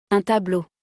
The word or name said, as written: Un tableau